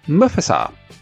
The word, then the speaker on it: Mufasa: Did you know that Sean Connery was Disney's first choice to play Mufasa? That's how you pronounce it.